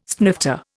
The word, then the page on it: snifter: Indian